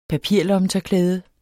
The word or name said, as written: papirlommetørklæde